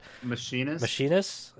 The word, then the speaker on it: Machinist: They added Machinist or Machine. I forgot how to fucking pronounce that. – Machinist.